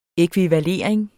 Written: ækvivalering

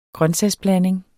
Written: grøntsagsblanding

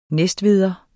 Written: næstveder

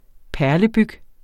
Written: perlebyg

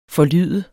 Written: forlyde

